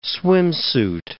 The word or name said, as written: swimsuit